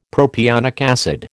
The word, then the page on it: propionic acid: American English